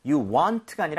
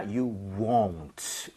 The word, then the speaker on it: won't: 자, 그리고 won't 할 때는 입모양을 많이 모아야 돼요. 그래서 you want가 아니라 you won't 이렇게 won't. 꼭 발음하시길 won't. 바라겠습니다.